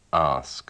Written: ask

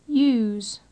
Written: use

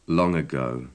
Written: long ago

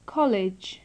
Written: college

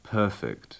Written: perfect

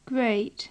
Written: great

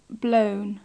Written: blown

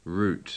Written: root

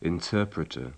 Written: interpreter

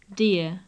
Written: dear

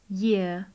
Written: year

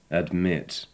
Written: admit

